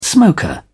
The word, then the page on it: smoker: British English